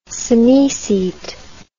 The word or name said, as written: synoecete